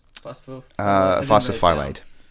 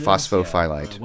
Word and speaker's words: Phosphophyllite: Phosphophyllite. – Is that what it is? – Phosphophyllite.